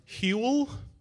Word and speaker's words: Huel: Huel? – Yeah. Yeah. Yeah. It even rhymes with gruel.